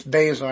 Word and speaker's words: Baszler: I wanted to say Baszler or Baszier, but it's Baszler.